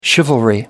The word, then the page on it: chivalry: American English